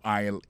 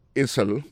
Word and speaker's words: aisle: Aisle là, c'est A-I-S-L-E. Vous prononcez l'aisle. Il marque Essel.